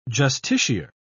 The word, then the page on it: justiciar: British English